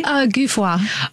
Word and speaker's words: Guffroy: Am I saying it correctly? – Guffroy. – Oh. – It's French.